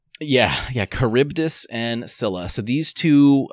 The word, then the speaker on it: Charybdis: We're going to be talking about Charybdis and Scylla or Scylla. I've seen it, but Charybdis, I think, is the way to pronounce.